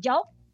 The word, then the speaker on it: The Joe: Salió con este seudónimo que se llama Joe, o sea, se escribe como The Joe. Bueno, pues no sé, pero dicen que se pronuncia solo Joe.